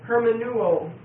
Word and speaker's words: hermeneuo: The Greek word is hermeneuo, H-E-R-M-E-N-E-U-O. And it's a long E and a long O. Hermeneuo.